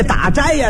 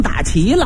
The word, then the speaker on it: 打斋: 这打斋呀，他给念成打琪了，斋字他念成琪了。